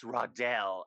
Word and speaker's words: Randell: R-A-W-D-E-L-L-L, pronounced Rodell.